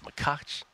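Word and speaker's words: Machac: It's actually pronounced Machach.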